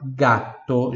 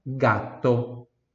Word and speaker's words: gatto: É, então, gato, G-A-T-T-O. A única diferença também é, aqui na escrita é que tem um T a mais, é uma consoante dupla, una doppia consonante. Allora gatto, G-A-T-T-O, repita em voz alta, gato, gato.